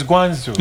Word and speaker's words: Guangzhou: Is it Guangzhou or Guangzhou? – It's Guangzhou. – It's not Zhu. Okay. – It's Guangzhou. – And the other one, the Y-I-W-U, is what? – We pronounce it in English.